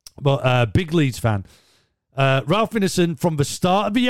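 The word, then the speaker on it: Ineson: Ralph Innison is how you say it.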